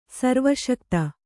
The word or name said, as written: ಸರ್ವಶಕ್ತ